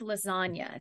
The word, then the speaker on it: lasagna: I know all Americans know the word lasagna. It's the same ending. – Yeah. You don't pronounce the G, in lasagna either.